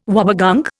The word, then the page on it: wobbegong: Indian